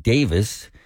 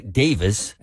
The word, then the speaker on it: Davies: And this All in the Family weekend, the Davies brothers, although Ray calls it Davis, they are Ray and Dave. Ray on lead vocals and rhythm guitar, Dave lead guitar and vocals, and Ray Davis, as he pronounces it, wrote that great hit and produced it as well.